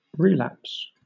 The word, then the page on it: relapse: British English